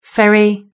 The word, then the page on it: ferry: British English